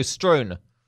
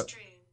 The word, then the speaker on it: strewn: Strewn. Feels a bit funny saying strewn. – I'll go. – Strewn. Strewn. Yeah. Wouldn't feel confident saying that. People wouldn't know what I meant. Strewn rhymes with noon.